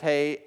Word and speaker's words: He: It's hey, H E which is spelled he, I know, but it's pronounced hey.